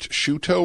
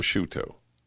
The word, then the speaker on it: Sciutto: And Jim, is it Shuto or Scuto? Shuto? Very funny. No, no, no. Jim Sciutto.